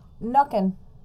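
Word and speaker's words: Nøkken: There are also old legends of Nokin. I don't know how to say this. – Nuckin. – Nuckin? – Nuckin. – Nuckin. Okay. Nuckin. Nuckin, it means, it translates to the neck.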